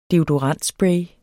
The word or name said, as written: deodorantspray